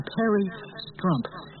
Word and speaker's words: Perry Strump: Perry Strump. – Huh? – Perry Strump. – Very it's Trump. – Yeah, okay. – It rhymes with Trump.